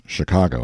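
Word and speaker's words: Chingachgook: For instance, one of his acute Indian experts, Shinkagook, pronounced Chicago, I think, has lost the trail of a person he is tracking through the forest.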